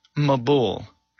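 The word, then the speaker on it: Mabul (מבול): There's a special Hebrew word pronounced Mabul.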